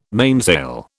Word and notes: mainsail: British English

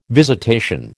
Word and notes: visitation: American English

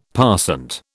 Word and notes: passant: British English

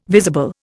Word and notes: visible: Indian

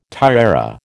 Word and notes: tiara: American English

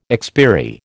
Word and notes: expiry: American English

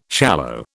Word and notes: shallow: British English

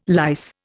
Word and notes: lice: Indian

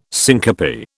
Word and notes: syncope: British English

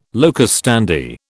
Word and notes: locus standi: British English

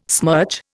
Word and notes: smirch: Indian

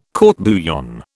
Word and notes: court bouillon: British English

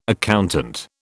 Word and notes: accountant: British English

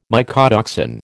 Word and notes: mycotoxin: American English

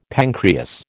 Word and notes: pancreas: American English

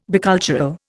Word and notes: bicultural: Indian

